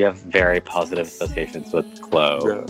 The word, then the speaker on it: Kllo: Back to You by Klo. That's spelled K-L-L-O.